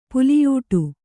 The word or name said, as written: ಪುಲಿಯೂಟು